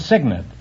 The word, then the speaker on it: cygnet: No, it's Signet. It's spelled C-Y-G-N-E-T, but it's pronounced Signet.